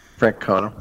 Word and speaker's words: Francona: Francona.